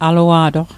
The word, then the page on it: alua'toq: Mi'gmaq